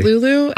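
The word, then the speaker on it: Luleå: But it's like L-U-E-A. L-U-E-A. But they pronounce it Lulu.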